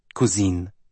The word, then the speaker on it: cousine: What's your name, cousine? He pronounced cousin like the French feminine.